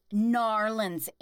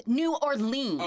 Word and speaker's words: New Orleans: And same with, it's Narlins, Aaron. No, it's New Orleans.